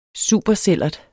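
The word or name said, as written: supersællert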